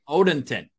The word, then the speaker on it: Odenton: I mean, you're from Odenton. I hope I pronounced that right, in Maryland. – Odenton.